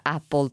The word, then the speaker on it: Apple: Sorrido mentre lo dico, perché ancora adesso alcuni ascoltatori si ricordano la piccola polemica, piccolissima, su come si pronuncia Apple, perché appunto si pronuncia con la A e non Apple, come spesso si sente dire in Italia, ma non è poi così grave, insomma nessuno è tenuto a sapere esattamente come si pronunciano le parole inglesi.